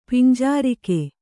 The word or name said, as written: ಪಿಂಜಾರಿಕೆ